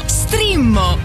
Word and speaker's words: Streammo: No, Strimmo! Entra adesso nel mondo di Strimmo. Vai su strimmo.it, si scrive Streammo o scarica l'app.